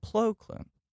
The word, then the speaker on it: Plo Koon: With the cockroach face, who we have been calling Plo Koon this entire time, yeah. – is in fact called Plo Koon.